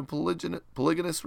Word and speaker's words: polygynous: Or a full and equal partner with Father in heaven in a polygynous. Is that how you say it? – Polygynous.